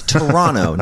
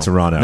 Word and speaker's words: Toronto: Yeah, Toronto. – Not Toronto. Is it? – I'm sure people pronounce it both ways. – I think they're both. – Hogtown. – They're both legal.